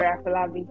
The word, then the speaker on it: Afolabi: Yeah, that's it, Ore Afolabi.